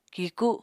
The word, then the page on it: kiku: Apatani